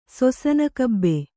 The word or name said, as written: ಸೊಸನಕಬ್ಬೆ